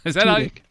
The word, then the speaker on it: Tudyk: Danny Pudi, Alan Tudyk. – Tudyk. – Tudyk? – Yes.